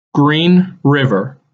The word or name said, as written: Green River